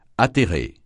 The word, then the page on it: atterrer: francais